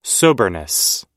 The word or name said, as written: soberness